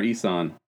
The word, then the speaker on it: Eason: Jacob Eason, is that how you say it? Eason or Eason? – Eason, yep. Eason.